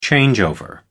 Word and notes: changeover: American English

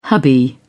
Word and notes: hubby: British English

